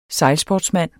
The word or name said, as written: sejlsportsmand